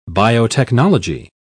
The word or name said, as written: biotechnology